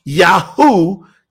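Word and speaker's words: Yahu: pronounced Yahoo, just like the search engine Yahoo.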